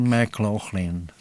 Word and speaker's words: McLaughlin: Der heisst John McLaughlin.